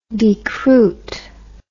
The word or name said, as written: decruit